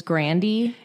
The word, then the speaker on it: Grande: It's by Ariana Grande. Is that correct? I feel like there was a big controversy where no one was saying it right. – I feel like recently she was like, actually, it's Grandy.